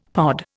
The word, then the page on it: pod: Indian